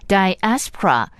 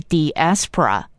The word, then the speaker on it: diaspora: 提醒您，在今天的节目中会提到一个英文字 diaspora，也有另外一个发音是 diaspora，